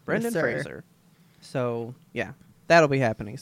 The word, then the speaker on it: Fraser: Fraser, sorry. He stated that in an interview I watched the other day. He said, Fraser. Rhymes with razor, and I went, oh, you got it.